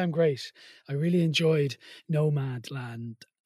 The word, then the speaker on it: Nomadland: It's one I could easily mispronounce. I keep going to call it No Man's Land from Indeed. – The seminal Billy Joel song of 1993, but it's Nomad Land.